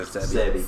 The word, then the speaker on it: Sebi: Dr. Sebi.